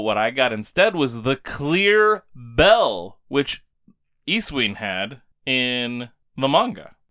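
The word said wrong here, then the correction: Eusine: Or Yusin, as I've since learned it's probably pronounced. Which actually makes sense. I don't know why I ever said Isuin, because EU... is the beginning of Europe, which is you.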